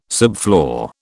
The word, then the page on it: subfloor: British English